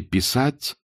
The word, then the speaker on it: писать: В режиме «писать» – ударение на «а» – вас ждет самое увлекательное и невероятное интеллектуальное приключение вашей жизни.